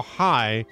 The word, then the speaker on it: Hey: Spelled H-E-Y, which would be Kyle Hey. – That's if he was on the Ron and Fez show. – But it's Kyle High. I just stop on names like that you pronounce it high and it's spelled H-E-Y.